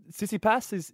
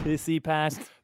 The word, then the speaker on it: Tsitsipas: I think Tsitsipas is how you say it. – Tsitsipas.